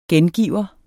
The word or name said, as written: gengiver